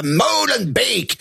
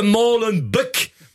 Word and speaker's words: Molenbeek: C'est juste que personne ne sait prononcer Molenbeek correctement. Les Français, ils disent Molenbeek. Molenbeek, et les Anglais, Molenbeek.